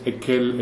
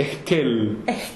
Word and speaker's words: Esquel: And we pronounce it Esquel. But they didn't get this H sound, and they just perceived Ekel. It must be very, very soft, this H, because some people who are not used to it simplify it that way, like as if it wasn't pronounced.